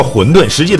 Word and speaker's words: 馄饨: 东北喜欢叫馄饨，实际它标准发音叫馄饨。